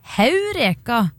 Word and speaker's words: heureka: I gammelgresk har de et aspirasjonstegn over «e» som viser at det skal uttales «heureka» med «h».